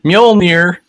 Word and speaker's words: Mjolnir: What is on the list, we have Thor's hammer, Mjolnir. – Yeah, I just call it Thor's hammer because I can't pronounce it. – You have to say it like your Toki Wartooth. Mjolnir.